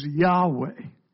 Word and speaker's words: Yahweh: That means Jehovah, or the Hebrew pronunciation is Yahweh.